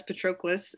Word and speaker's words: Patroclus: And nevertheless, Patroclus, is that how you say it? – Yep.